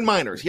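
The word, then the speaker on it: Meyers: Miners. Is that how you say it? – Yeah. Quinn Miners.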